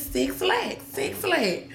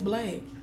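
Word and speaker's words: 6LACK: It's Six Lack. And then the girls in the shop, they were like, no, it's Black.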